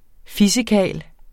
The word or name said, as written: fissekarl